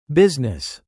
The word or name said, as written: business